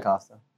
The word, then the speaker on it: Costa: Yeah, Costa.